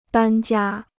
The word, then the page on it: 搬家: bān jiā